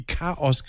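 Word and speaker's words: k-os: K-Bindestrich, großes O und dann S. Und es wird wie Chaos gesprochen.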